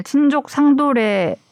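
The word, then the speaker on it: 친족상도례: 저희가 한숨을 이래 피디님이 저희가 오늘 친족 상도래 친족 상도래라고 읽는 거죠? – 예. 그렇죠. 네, 맞습니다. 네. – 친족상도래. 친족상 네. 도래 아닙니다.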